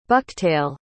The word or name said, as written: bucktail